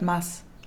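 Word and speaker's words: Mads: Ähm, und wir haben übrigens die ganze Zeit Mats falsch ausgesprochen. Wir haben ja Mats gesagt, weil man das in Deutschland so ausspricht, aber es ist halt Mass. Mass Mickelsen. Ja, Mass Mickelsen. Wie hm. in Christmas.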